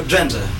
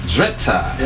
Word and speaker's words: Drenthe: Radio Drenthe. – Drenthe.